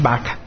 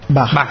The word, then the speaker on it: bug: Bueno, lo podemos llamar como os apetezca, pero realmente la pronunciación creo que es bug. – Bug, vale. Pero bueno, leído en castellano como bug.